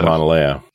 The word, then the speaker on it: Mauna Loa: Mauna Loa.